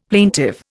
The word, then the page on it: plaintive: Indian